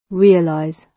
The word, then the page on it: realize: British English